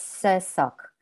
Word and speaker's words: Sursok: And the surname is Sursok. Like sock, you know, you wear a sock, so it's Sursok.